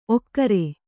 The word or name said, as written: ಓಕರ